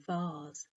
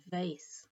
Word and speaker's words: vase: and vase, which in British English rhymes with the planet Mars, and in American rhymes with lace, so vase and vase.